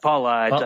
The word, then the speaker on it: Paulides: Paulides, I think it's pronounced.